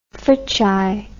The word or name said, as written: Fricsay